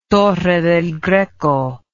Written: Torre del Greco

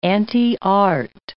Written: anti-art

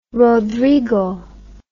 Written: rodrigo

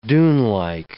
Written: dunelike

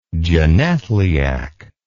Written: genethliacally

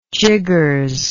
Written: jiggers

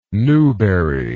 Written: newbery

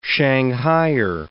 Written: shanghaier

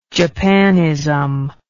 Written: japanism

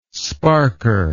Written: sparker

